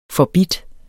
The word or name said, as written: forbidt